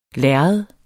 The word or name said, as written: lærred